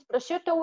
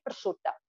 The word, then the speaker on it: prosciutto: Yes, in Italian it's prosciutto. We say prosciutta. Prosciutta. It's very similar.